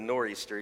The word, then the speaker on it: Nor'easter: And in Acts 27, verse 14, it says this, but before long, a fierce wind called the Northeaster, if you're from New England, the Nor'easter, you don't pronounce the TH, rushed from the island.